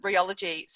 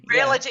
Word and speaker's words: Realogy: and you are now the president of the Realogy. Sorry, I keep saying that incorrectly. The Realogy. – Realogy. – Yeah. Thank you.